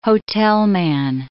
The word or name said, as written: hotelman